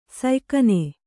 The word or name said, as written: ಸಯ್ಕನೆ